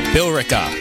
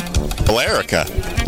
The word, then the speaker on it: Billerica: Bill Eric. It's Billerica? Bill? What? How do you pronounce that? There's an E in there. – Billerica. – Billerica, maybe? – Billerica. – Oh, that makes sense. Just leave out the E.